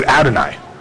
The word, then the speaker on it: Adonai: I was taught when I was learning Hebrew, when you run across YHWH in Hebrew, you pronounce it Adonai, which breaks all the rules of Hebrew, but you don't pronounce it.